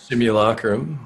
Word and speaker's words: simulacrum: Yeah, and the names of her quest are Balance Unto All and a Deific Simulacrum. How do you say that word? Simulacrum? – Deific Simulacrum. – So, is it Simulacrum? Is that actually? – Yeah, Simulacrum is something that is like a fake copy.